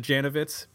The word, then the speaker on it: Janovitz: Is it Janovitz, Bill? Did I say that right? – Yeah, you got it. Yeah, perfect.